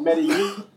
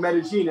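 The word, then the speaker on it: Medellín: And Colombians pronounce double L's as a J. So whereas in Spain or Mexico, it might be called Medellin, here it's called Medellin. And it's the most charming expo in the world, Expo Medellin.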